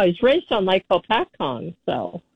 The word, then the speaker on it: Hopatcong: And I remember when President Johnson decided they were going to drain Lake Hopatong. He mispronounced it because there was a drought.